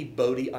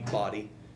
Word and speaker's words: Bodie: And by the way, it should be Bodie Island Lighthouse. It should not be Body. Whoever decided it should be pronounced Body is wrong, okay? If it was Body, it would be spelled differently.